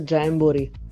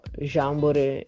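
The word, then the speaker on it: Jamboree: Si pronuncia Jambori, però Jambore ci piace lo stesso.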